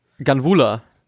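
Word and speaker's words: Ganvoula: Wie spricht man den Kollegen aus mit der 35? Guvu? Govu? – Ganvula.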